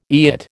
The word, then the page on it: eyot: American English